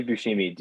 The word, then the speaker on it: Buscemi: Yeah, Steve Buscemi.